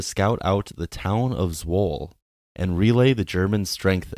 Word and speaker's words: Zwolle: So now, let's dive into the liberation of Zwolle. Did I pronounce that right? – Yes. – Okay, thank goodness. I did not double-check that before we hit record. – It's Zwolle.